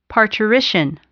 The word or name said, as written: parturition